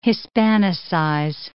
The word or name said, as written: Hispanicize